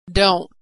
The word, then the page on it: don't: American English